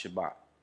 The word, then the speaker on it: Shabbat: The word Sabbath, follow me, let me break this down in Hebrew, it's pronounced Shabbat.